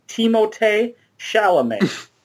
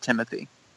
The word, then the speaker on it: Timothée Chalamet: And I'm going to pronounce it the way I've heard it's actually pronounced, Timothée Chalamet. Call me by your name. Yeah, even though he's born in, I think he was born in New York City. In fact, he's the only American amongst these five. – So I read an interview this past week that he actually pronounces it Timothy.